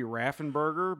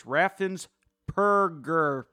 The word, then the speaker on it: Raffensperger: I wanted to say that correctly because it could be Raffenberger, but it's Raffensperger with a P, Perger, like burger with a P.